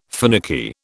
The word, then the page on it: finicky: British English